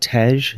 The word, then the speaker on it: Tej: Is it Tej, the Ethiopian honey wine? – Yep.